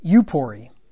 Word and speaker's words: euporoi: No, not empori. Eupori. E-U-P-O-R-O-I.